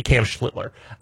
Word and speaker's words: Schlittler: I pronounced Cam Schlittler's name wrong, so I do apologize. I said, I'm thinking of Art Schlitter, obviously, Cam Schlittler.